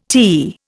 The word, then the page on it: tea: Indian